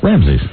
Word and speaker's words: Ramses: Is it Ramses? – Ramses, right.